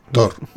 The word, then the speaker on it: Thor: Thor. – Thor.